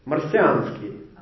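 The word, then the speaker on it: Marciansky (Марсианский): In Russian, Martian and Messianic are spelled similarly and are pronounced almost the same. Messiansky, it's Messianic. Marciansky, Martian.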